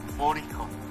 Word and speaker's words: Mourinho: Mourinho.